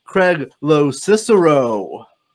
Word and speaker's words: Craig Locicero: Please welcome Craig Locicero.